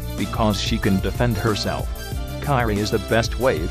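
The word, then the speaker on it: Kairi: Kyrie, her name is pronounced Kyrie, the one to see.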